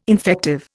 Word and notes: infective: Indian

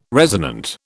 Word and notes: resonant: British English

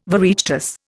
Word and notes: Oireachtas: Indian

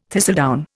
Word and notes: thistledown: Indian

